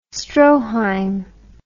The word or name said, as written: Stroheim